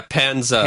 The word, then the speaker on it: Pandza: Panzda. How do you say that? P-A-N-D-Z-A. Panzda. Panzda. – Yeah, Panzda.